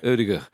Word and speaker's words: Ødegaard: Ødegaard.